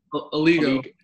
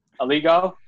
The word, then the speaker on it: Aligo: Like how do you say it? – Aligo. – Aligo. Okay, yeah.